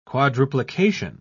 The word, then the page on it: quadruplication: British English